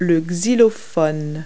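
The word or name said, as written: xylophone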